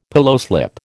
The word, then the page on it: pillowslip: American English